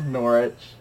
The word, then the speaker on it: Norwich: It's just pronounced Norwich.